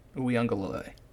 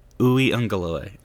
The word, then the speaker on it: Uiagalelei: Uiagalelei. – Uiagalelei. I got it. – Uiagalelei.